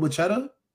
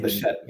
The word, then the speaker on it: Bichette: Bo Bichetta. But how do you say his last name? – Bo Bichetta. – On the Blue Jays. – Oh, Bo Bichette. – Okay. – Last name Bichette.